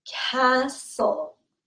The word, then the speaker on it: castle: Next word is castle. Castle. A really big house is a castle, and it is spelled C A S T L E, and the T is totally silent.